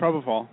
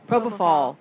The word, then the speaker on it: propofol: Propofol. – Propofol.